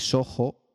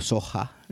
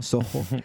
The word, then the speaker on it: SOHO: Decir simplemente que SOHO, para la gente que se puede pronunciar como SOHA, ¿no? SOHO, S-O-H-O, son las siglas de Small Office / Home Office.